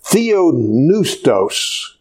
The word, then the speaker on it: theopneustos: Now, the Greek word that is translated by the inspiration of God is the Greek word theopneustos.